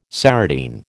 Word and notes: serotine: American English

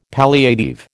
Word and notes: palliative: American English

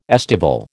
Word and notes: estival: American English

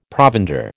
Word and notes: provender: American English

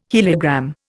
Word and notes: heliogram: Indian

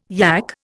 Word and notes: yack: Indian

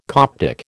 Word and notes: Coptic: American English

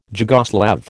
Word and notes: Jugoslav: American English

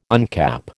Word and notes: uncap: American English

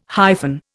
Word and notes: hyphen: Indian

